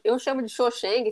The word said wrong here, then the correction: Cho Chang: se você quiser corrigir minha pronúncia. – É, o Cho, Cho, né? Cho Chang.